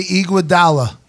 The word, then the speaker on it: Iguodala: I think Andre Iguodala, is that pronounced right? – Yes. – Yep. – Close. Mm-hmm. I heard it both a different way. – Iguodala.